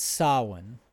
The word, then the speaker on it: Samhain: I'd also like to mention here that the other Sabbat midpoint is Samhain, and it looks like it's pronounced Samhain, the way it's spelled, Samhain, but it's Samhain.